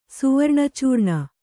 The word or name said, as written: ಸುವರ್ಣಚೂರ್ಣ